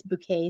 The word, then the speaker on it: Bouquet: And it's Bouquet spelled like bucket, right?